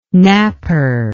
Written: napper

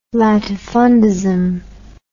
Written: latifundism

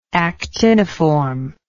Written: actiniform

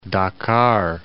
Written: Dakar

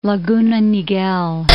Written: Laguna Niguel

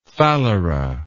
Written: phalera

